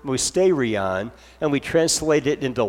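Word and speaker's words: mysterion (μυστήριον): Well, we say that because both the word sacrament and mystery are derived from the same Greek word, pronounced mysterion, or some people say it a little bit different, mysterion. You could pronounce it either way.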